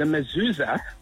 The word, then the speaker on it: mezuzah: So what I want to tell you is the mezuzah, you pronounce it correctly. Well done.